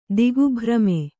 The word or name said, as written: ದಿಗ್ಭ್ರಮೆ